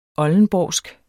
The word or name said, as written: oldenborgsk